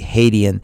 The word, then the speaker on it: Hadian: So again, my name is spelled S-H-A-H-R-A-M. That's the first name, Sairam. And then H-A-D-I-A-N as in Nancy, Haydian. So if they just look up Sairam Haydian on Rumble, they will find our page, and we'll be uploading that, as well as all of our Truth Today shows.